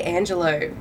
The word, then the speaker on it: Angelo: Fuck, Chloe Angelo, welcome to this podcast. Angelo. Did I say it right? Like D'Angelo? – Yes.